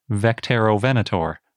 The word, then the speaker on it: Vectaerovenator: And fantastically for me, they wrote, quote, We imagine the name to be pronounced Vectero Venator.